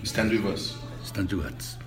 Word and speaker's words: Stand With Us: Stand With Us.